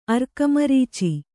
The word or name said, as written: ಅರ್ಕಮರೀಚಿ